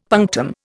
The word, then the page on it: punctum: Indian